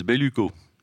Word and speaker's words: Belluco: Et puis, Lisa, on dit Belluco, comment on le prononce? À la française, Belluco, qui est au chômage en ce moment à cause du 49-3.